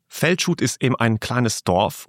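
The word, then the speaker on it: Felcsút: Wie spricht man den Ort aus? – Feldschut, und es Feldschut ist ein Dorf von ca.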